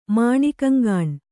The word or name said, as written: ಮಾಣಿಕಂಗಾಣ್